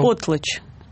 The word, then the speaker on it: потлач: С точки зрения антропологии, в сжигании продуктов есть действительно много общего с традициями первобытных времен, в частности, с ритуалами потлач у североамериканских индейцев. – Еще раз, как называется ритуал? – Потлач. – Потлач. – Потлач. Ударение на «о».